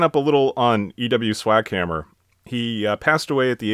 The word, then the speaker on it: Swackhammer: Is that really how you pronounce it? – Yes. – I don't think there's any other way you could pronounce it. – I was thinking maybe like Sfockhammer, but...